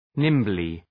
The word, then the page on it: nimbly: ‘nımblı